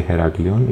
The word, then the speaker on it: Heraklion: If the signs are old, they say Heraklion.